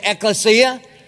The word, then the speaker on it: Ecclesia: That's how you say it. Everybody say Ecclesia. That's a Greek word, and the word is now translated church.